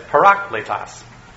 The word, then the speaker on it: parakletos: Now, I didn't know how to say it until I pushed the little button, but it's parakletos.